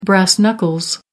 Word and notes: brass knuckles: American English

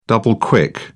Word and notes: double-quick: British English

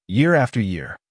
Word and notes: year after year: American English

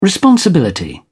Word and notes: responsibility: British English

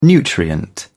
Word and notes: nutrient: British English

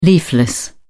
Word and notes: leafless: British English

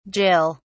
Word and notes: jill: British English

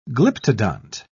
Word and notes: glyptodont: British English

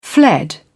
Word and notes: fled: British English

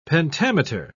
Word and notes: pentameter: British English

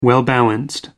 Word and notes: well-balanced: American English

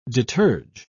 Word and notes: deterge: British English

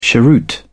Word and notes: cheroot: British English

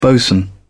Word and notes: bosun: British English

